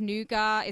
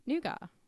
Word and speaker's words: nougat: Is that how you guys pronounce it in the UK? – That's how I fucking say it. No, because like there's a difference because in France, there's a nougat and this is a nougat. – Well, in the US, we say nougat. – Yeah, well, you guys also say pecan. – All right, people can call in about this, but the proper way to say it is nougat, not nougat.